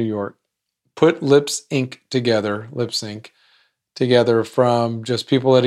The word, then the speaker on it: Lips Inc.: One, the band is called Lips Inc. I-N-C. But it's pronounced Lipsync. Get it?